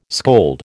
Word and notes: scold: American English

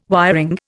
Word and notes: wiring: Indian

